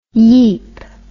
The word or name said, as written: yeep